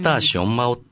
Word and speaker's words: 大熊猫: これは中国で大熊猫と書いてですね、タアシオンマオっていうんですよね。